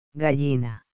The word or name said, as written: gallina